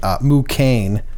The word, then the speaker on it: Mukane: Rhymes with Liu Kang. – Yeah. – One of the best. – Literally, so Mu and then K-A-N-E. Mu Kane.